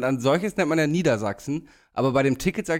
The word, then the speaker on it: Niedersachsen: Betonung, mit Betonung auf Sachsen, Niedersachsen. Und wenn ich nur das Bundesland sage, mache ich die Betonung auf Nieder.